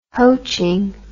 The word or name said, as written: hoatching